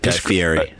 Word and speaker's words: Guy Fieri: The topic of work masturbation breaks come up in the wake of a survey by Guy Fee. I don't know how that's pronounced. – Guy Fieri.